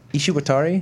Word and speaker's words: Ishiwatari: Anyway, last week, Ishiwatari, am I pronouncing that right? – Yes, you actually are.